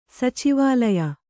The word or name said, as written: ಸಚಿವಾಲಯ